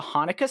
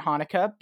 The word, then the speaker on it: Hanukkah: So in Hebrew, it is pronounced Hanukkah, but many English speakers aren't familiar with that sound that's at the beginning of it, because it's not a sound that occurs in English.